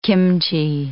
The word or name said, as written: kimchi